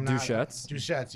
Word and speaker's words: Deschutes: Forgot how to say this name. Duchettes. Yeah, there we go.